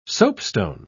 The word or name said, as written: soapstone